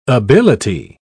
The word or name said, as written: ability